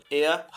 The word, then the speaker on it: er: E afirmando, er hat. Se escreve er, mas você pronuncia o R ao final como um A.